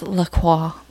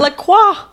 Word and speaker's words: LaCroix: And I know it's pronounced LaCroix, but I can't bring myself to say that. – LaCroix.